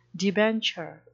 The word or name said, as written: debenture